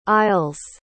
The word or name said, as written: isles